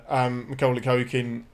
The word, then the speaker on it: Macaulay Culkin: Say his name again. Because I think you've pronounced it differently every time you said it. – Macaulay Culkin? – Perfect.